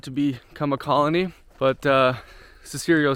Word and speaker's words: Cicero: All right, so Cicero,